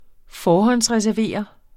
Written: forhåndsreservere

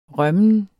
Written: rømmen

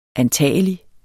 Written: antagelig

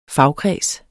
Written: fagkreds

